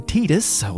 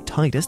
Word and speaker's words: Tidus: Because if you dig a little deeper into this game and look at what was originally intended, then Tidus, or Tidus, depending on how you want to pronounce it, was actually a summon similar to the Aeons.